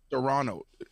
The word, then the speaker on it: Serrano: Serrano.